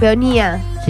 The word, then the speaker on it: peonía: Es una flor azul, es una peonia pintada en acuarelas. – Una peonia. – ¿Una qué? – La begonia. Peonía. Sí, ahí está, sí. Lo dije bien.